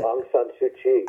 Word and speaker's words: Aung San Suu Kyi: excuse me if I say her name wrong, Ansun Lee. oh, Aung San Suu Kyi.